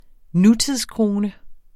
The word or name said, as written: nutidskrone